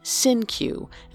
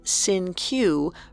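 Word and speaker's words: Cinque: Although Donald mispronounced the name as Sinkew rather than the original Latinate Sinke.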